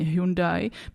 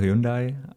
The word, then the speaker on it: Hyundai: Hele, pro cizince je to Hyundai, ale mezi Korejcemi je to Hyundai.